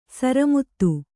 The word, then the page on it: ಸರಮುತ್ತು: kannada; sara muttu